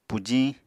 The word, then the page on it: pujiñ: apatani